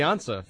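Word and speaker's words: Ansah: He might be the Ansa for the Patriots at defensive end.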